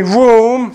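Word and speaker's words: room (רום): In the Hebrew, what we just quoted from Isaiah 6, verse 1, the Lord sitting on a throne, high, it's the Hebrew word room, you would pronounce it room, like we're sitting in this big room, right?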